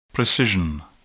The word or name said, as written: precision